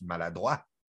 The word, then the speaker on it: Maladroit: Which actually in French you pronounce maladroit, which means it means being clumsy.